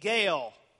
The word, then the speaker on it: Gaal: The people of Shechem rally to a new man named Gaal, rhymes with Baal,